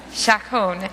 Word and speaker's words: Chaconne: Chaconne.